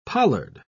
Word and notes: pollard: British English

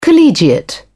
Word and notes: collegiate: British English

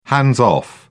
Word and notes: hands-off: British English

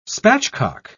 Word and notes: spatchcock: British English

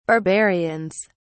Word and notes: barbarians: British English

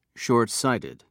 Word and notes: shortsighted: British English

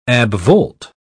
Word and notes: abvolt: British English

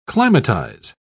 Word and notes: climatize: British English

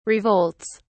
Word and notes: revolts: British English

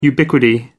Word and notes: ubiquity: American English

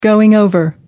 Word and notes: going over: American English

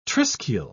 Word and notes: triskele: British English

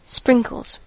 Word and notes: sprinkles: American English

